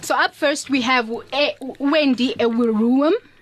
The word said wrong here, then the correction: Ewurum: Did I say that correctly? – Almost. Ewurum. – Ewurum, yes. Yes, Wendy.